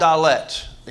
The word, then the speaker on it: Dalet: The second one's called Dalet, and these are all pretty phonetically spelled, D-A-L-E-T.